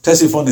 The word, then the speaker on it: Ctesiphon: Ctesiphon is now a dead name. It's actually got a silent C. You know, I don't know why they got these silent letters. Ctesiphon.